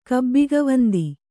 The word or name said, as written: ಕಬ್ಬಿಗವಂದಿ